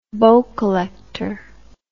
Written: bow collector

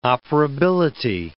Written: operability

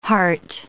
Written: hart

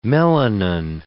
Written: melanin

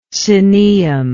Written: cenaeum

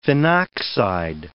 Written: phenoxide

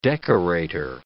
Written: decorator

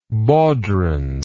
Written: baudrons

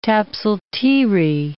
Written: tapsal-teerie

